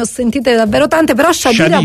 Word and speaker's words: Khedira: Allora, ci hanno detto che la pronuncia di Khedira è Shadira. Ora ci vogliamo attenere o vogliamo continuare a dire Khedira? No, ci dobbiamo attenere a quello che ha detto Walid Khedira. – Infatti, perché si pronuncia Khedira.